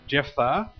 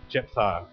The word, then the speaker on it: Jephthah: I did a bit of research on that, and I kept on saying Jephthah with a PH, but I'm assured that it's Jephthah.